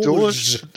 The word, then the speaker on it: Doge: Äh, ja, das heißt richtig, Doge. Doge.